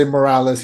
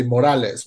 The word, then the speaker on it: Morales: He doesn't say Morales, he say Morales, which is the right way to say it.